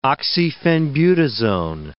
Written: oxyphenbutazone

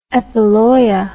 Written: epiloia

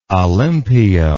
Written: olympio

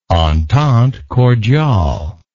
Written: entente cordiale

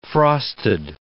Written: frosted

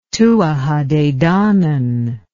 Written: Tuatha de Danann

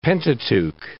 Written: Pentateuch

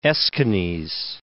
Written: Aeschines

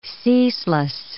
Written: ceaseless

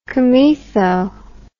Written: comaetho

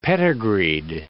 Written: pedigreed

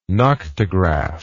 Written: noctograph

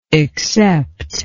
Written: exceptable

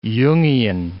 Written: Jungian

